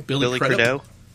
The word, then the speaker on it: Billy Crudup: Billy Crudup. Billy Crudup. – Billy Crudup. Well, she was like, I changed my mind. Well, actually, can I have them both? I'd like Idris and Billy Crudup.